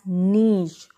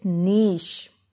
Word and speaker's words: niche: It is pronounced as niche. All of you listen to me very carefully. It's niche.